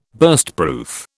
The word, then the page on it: Burstproof: British English